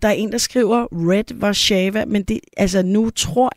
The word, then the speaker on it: Red Warszawa: Red Warszawa udtaler deres eget navn som Red.